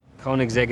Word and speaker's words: Koenigsegg: They're Koenigsegg Agueras, I think. – Yeah, that's exactly how it's pronounced, yeah. – Koenigsegg.